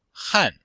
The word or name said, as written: han4